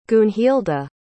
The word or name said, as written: gunhilda